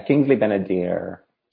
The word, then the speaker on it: Kingsley Ben-Adir: Am I saying his name right? – Yeah, Kingsley Ben-Adir.